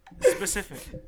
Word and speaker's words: specific: Do you mean specific? – Pacific. – Specific. – Huh? – You said Pacific a couple of times. – So wait, say it. – Specific.